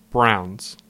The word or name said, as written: Browns